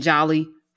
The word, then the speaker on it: Djali: Uh, D, D, D O M A is Doma, and Jali is a couple of ways to spell it, but probably the most common way is either J A L I or you see the D, which is silent, D J A L I.